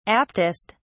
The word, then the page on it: aptest: British English